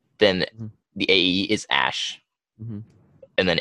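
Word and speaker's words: Æ: So the AE thing is pronounced Ash.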